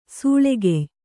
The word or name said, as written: ಸಿಳ್ಳೆಕಾಯ